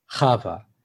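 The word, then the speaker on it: Chava (חוה): I should say Eve is I don't know exactly how English got to Eve, but the name as we have it is Chava. I guess if you imagine Chava and then you take away the ch sound at the beginning, so it becomes Ava, which is similar to like in Romance languages translations, I think.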